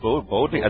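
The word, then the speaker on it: Boudica: How do you say this name? Bodega. Bodega. – I think it's Bodega. I think you're saying it right.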